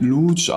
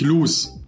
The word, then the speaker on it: Luce: Der wird Luce ausgesprochen und nicht Luce.